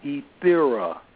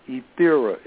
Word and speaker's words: Ethera: Okay, The E in my name stands for Ethera. I'll pronounce it again, Ethera. It's like the word ether with an A on the end, Ethera.